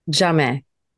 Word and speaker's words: Jaime: How do you say your name in French? – Jamais. – Jamais. So beautiful. – Or j'aime. Yeah. Oh, j'aime. Jamais. Yeah. – And that means I love.